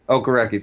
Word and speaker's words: Okereke: Okereke, thank you.